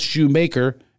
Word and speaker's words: Shoemaker: I hate, people still call me Schumacher. It's spelled Shoemaker, and always has been, by the way.